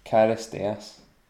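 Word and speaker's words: Charisteas: Is that Angelo Christianis or something? – Yeah. – I don't know exactly how to say his surname. – Karisteas. Yeah. That's right.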